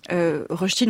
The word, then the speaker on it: Rösti: R au tréma, donc R-S-T-I, ch'ti. – La prononciation est bonne ou pas ? – Rösti. – Rösti. – C'est ça ? Ouais.